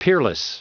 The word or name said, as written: peerless